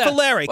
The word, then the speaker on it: Cavallari: The former Bears quarterback with his wife, Kristen Cavallari, excuse me if I'm butchering that name, were actually Cavallari. – Stuck. – Cavallari.